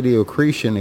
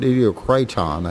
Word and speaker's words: Idiocreiton: It is from, well, I keep wanting to say Idiocretian, except they spell it Idiocriton.